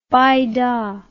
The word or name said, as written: bida